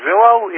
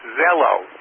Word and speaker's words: Zello: I think you're pronouncing it Zillow. It's Zello, Z-E-L-L-O, like hello, but with a Z at the front.